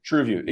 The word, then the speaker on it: TrueView: So, and on YouTube, you said those ads are called TrueView. Am I saying that right? – TrueView, exactly.